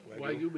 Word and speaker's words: Wagyu: Wagyu? – Yep. Wagyu beef.